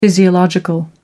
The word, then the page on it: physiological: American English